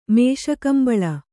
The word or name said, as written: ಮೇಷಕಂಬಳ